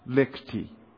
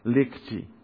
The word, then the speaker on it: Lichty: And of course, tomorrow afternoon, how do you pronounce Carol's last name? Lichty. Oh, that's going to be hard for an American. Lichty.